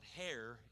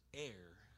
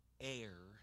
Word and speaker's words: heir: Now, if you don't understand that heir terminology, it's not hair, it's heir. H is silent, heir. H-E-I-R.